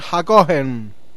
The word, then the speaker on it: Hakohen: Eliezer ben Nissan Hakohen. Sabemos que esa H se aspira, no es como ja.